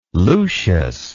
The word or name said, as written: Lucius